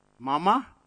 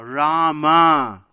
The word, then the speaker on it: Rama: And Valmiki said, "What? Mama? No, no, no, Rama. Karma? No, no, no, Rama."